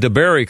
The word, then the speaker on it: DeBary: We're talking to Angie Zuccart of DeBerry Diner. I am pronouncing DeBerry correct, right? DeBerry, Florida? – You are. – It's spelled DeBary, Florida.